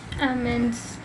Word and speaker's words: almonds: Inom kami with chicken skin, saka almonds. – Chocolate coated almonds. So, shal. – Almonds. – Tama ang pronunciation.